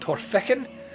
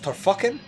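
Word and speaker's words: Torphichen: in a place called Torfikken, which is regularly mispronounced by people from other countries as Torfucken.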